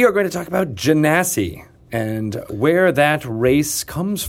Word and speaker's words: Genasi: You pronounced it correctly. – I did. Okay. Yes. Phew. – What? – Wait, so it's derived from genie? – Yep. – Is that correct? – That's correct. – Genasi, not Genasi.